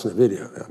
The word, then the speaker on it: NVIDIA: Even if you're right 5 times out of 10, if you own Costco or Walmart or I can't pronounce NVIDIA. I'm getting close to being able to pronounce it. – I think you nailed it there.